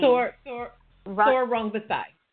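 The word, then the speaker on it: Sor Rungvisai: What's the guy's name that beat Chocolatito? I don't want to butcher his name. – Thor Rungvisai.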